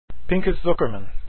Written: Zuckerman

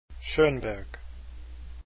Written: schoenberg

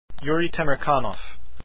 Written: Temirkanov

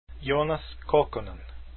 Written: Kokkonen

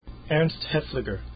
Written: Haefliger

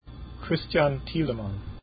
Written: Thielemann

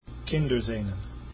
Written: Kinderszenen

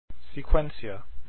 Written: sequentia

